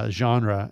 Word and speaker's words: genre: And so he's got a great sound that's very unique in the Christian genre. And so I've always wanted to use that word, genre. – You used it very well. It was a very nice pronunciation of that word.